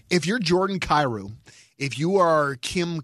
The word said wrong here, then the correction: Klim: Kostin, isn't that Kostin? Clem. Uh, Clem, Klim, Kostin.